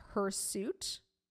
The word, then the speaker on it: hirsute: Okay, it's spelled H-I-R-S-U-T. It's apparently pronounced hirsute.